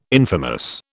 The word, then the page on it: infamous: British English